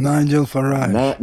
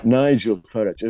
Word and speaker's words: Nigel Farage: İngiltere'de de Nigel Farage, onu da mı yanlış telaffuz ettim bilmiyorum. – Nigel Farage. – Nigel Farage, evet.